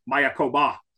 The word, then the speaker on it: Mayakoba: My personal favorite down there is Greg Norman's Mayakoba. And I learned to say that after about 11 years, the emphasis down at the back of the word Mayakoba.